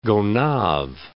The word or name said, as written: Gonave, Gulf of